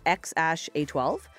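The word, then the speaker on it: X Æ A-12: Actually, I just learned how to pronounce it. It's XASH-A12.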